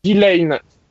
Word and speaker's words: Delain: Pardon, le deuxième, c'est Delain ou Delain ? – Delain. Ça s'écrit D-E-L-A-I-N.